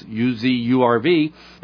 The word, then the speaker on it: UZURV: Likewise, if you type in UZURV, rhymes with reserve, but it's UZURV, it's both in the Apple Store and the Google Play Store, and we're growing daily, weekly.